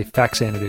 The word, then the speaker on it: Faxanadu: We just call it, we'd say Faxanadu because it was, because we were. – But it's kids. – Not spelled with a Z? – No, it's with an X. – Where the heck do they get FAX and go Faz? – Yeah, it's both Xanadu and then FA Xanadu for Famicom Xanadu.